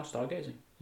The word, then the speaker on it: Stargazing: Ja, Stargazing.